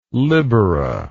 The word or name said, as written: libera